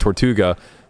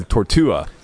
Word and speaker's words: tortuga: Yeah, so instead of pronouncing it tortuga, because he doesn't actually know the word, he started saying tortua.